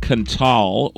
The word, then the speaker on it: Qntal: It's the band Kental, which suffers from an extreme lack of vowels. It's spelled Q-N-T-A-L.